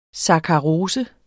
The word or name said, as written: sakkarose